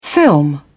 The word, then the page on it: film over: British English